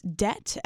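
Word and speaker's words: De()t: Night two is going to be headlined by Truth Club, and Junior Astronomers will be direct support, playing third, opened by Debt, pronounced Debt, as in we are in debt, but it's spelled D-E, two parentheses T, and then after that will be Black Surfer.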